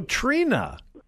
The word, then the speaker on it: Trina: Well, is it Trina? Oh, Trina. – Yeah. – So the I is silent.